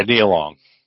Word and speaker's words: Nia Long: Is it Nia Long? I want to get her name right. Yeah, Nia Long.